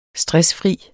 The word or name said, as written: stressfri